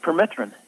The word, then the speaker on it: permethrin: People think of spraying their pants and shoes with the permethrin. Is it? Am I saying it right? – Permethrin, yeah. – Yeah, permethrin.